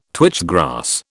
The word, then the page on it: twitch grass: British English